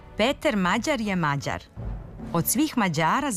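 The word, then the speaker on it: Péter Magyar: Ali u elektronskim medijima prezime mađarskog premijera akcentuje se drugačije, onako kako smo čuli na početku emisije. – Peter Mađar je Mađar.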